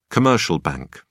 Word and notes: commercial bank: British English

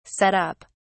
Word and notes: set up: British English

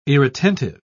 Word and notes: irretentive: British English

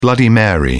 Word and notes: bloody mary: British English

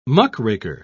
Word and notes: muckraker: British English

